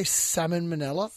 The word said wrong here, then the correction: salmonella: Salmonella. – Salmonella? – Salmonella. – Isn't the L... – It's too early for this. – The L is silent, I believe, okay?